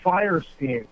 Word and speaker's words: Fierstein: Harvey Fierstein. Fierstein. – Yeah, well, some people say, I don't know, there are different versions of that. What about him? – That's the way to pronounce his name, Harvey Fierstein.